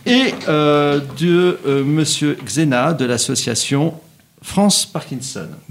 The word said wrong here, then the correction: Zénia: Est-ce que j'ai bien prononcé votre nom ? – Non, c'est Zénia. – Zénia, excusez-moi.